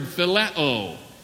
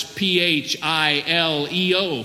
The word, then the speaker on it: phileo: Then there is a third word which we pronounce in the Greek language by the word phileo, spelled out it's P H I L E O, phileo.